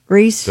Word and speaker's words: Rhys: Does he pronounces it Rhys? – Rhys. – Does he? I don't know. – Oh, it is Rhys.